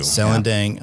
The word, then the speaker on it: Selendang Ayu: and correct me on my pronunciation. Selendang Ayu is that how we're Selendang Ayu? – Selendang yeah. IU, yeah.